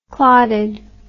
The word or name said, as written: clotted